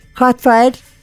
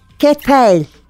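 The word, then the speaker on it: Cadfael: Katfael araştırdığım kadarıyla Ketfael diye okunuyor.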